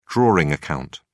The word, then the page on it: drawing account: British English